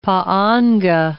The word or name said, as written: pa'anga